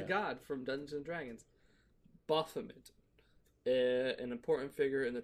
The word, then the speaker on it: Baphomet: Scudder also fashioned a stained glass window adorned with the prophet known as Bahamut or Is it no? – Baphomet. – Yeah, it was a Baphomet.